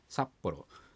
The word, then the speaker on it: 札幌: サで一つの音でして、そこにアクセントがありますね。ですから札幌、札幌というふうに発音すればいいと思います。